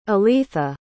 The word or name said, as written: aletha